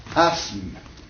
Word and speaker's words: asthme: À tel point que dans les textes, vous trouvez, au lieu de I-S-M-E, vous trouvez I-Z-M-E. Voyez-vous? Prenons un mot qui indique une maladie respiratoire. – Asthme. – Hein? Donc, il a aussi, n'est-ce pas? S-M, n'est-ce pas? faisons, écartons le TH de l'orthographe, n'est-ce pas, qui ne se prononce pas.